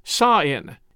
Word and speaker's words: Samhain: They say Celts invented Halloween, sort of, as Halloween tradition originated with the ancient Celtic festival of Samhain, which is spelled Samhain, like S A M H A I N, Samhain, but it's pronounced Sain, S A A I N.